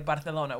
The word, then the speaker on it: Barcelona: Yeah, you got to roll the R. It's the same as saying as like Barcelona.